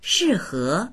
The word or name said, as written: shi4he2